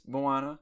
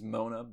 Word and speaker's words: Moana: There's Moana, often mispronounced as Mona by older white women.